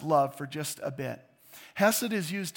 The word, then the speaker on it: hesed: The Hebrew word here is in this verse is hesed. That's how it's pronounced, hesed.